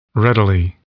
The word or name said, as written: readily